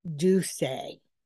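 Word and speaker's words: D'USSÉ: He sold his 50% stake in D apostrophe U S S E, pronounced Duse, to its parent company, Bacardi, for $750 million.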